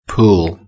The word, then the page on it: pool: British English